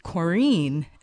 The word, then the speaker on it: Corinne: My name's actually French. It's Corinne and not Corinne.